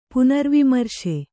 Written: ಪುನರ್ವಿಮರ್ಶೆ

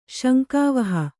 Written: ಶಂಕಾವಹ

